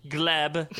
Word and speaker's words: Gleb: Erica Jane partnered with Gleb. Is that how you say his name? – Yep.